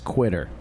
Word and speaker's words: Quitter: He spells it the number 2UITTER, which looks like Twitter if you were to pronounce it, but it's actually supposed to be a 2 like a cursive Q, I guess. It's Quitter.